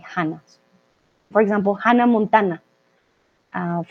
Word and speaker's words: Hannah: So if we say the, if we see the H, although we don't pronounce it, we say Hannah.